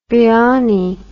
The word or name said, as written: gyani